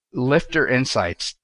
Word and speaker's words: Liftr Insights: And you are now the principal analyst at Liftr Insights.